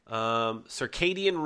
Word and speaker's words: circadian: Circadian rhythms.